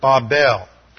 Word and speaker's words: Babel: In the Hebrew, it uses the word Babel. The king of Babel, or Babel, as we usually pronounce it, is mentioned in verse 1.